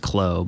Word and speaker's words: KLLO: It's pronounced Clo, but it's spelled KLLO.